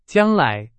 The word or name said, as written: 将来